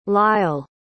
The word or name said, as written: lile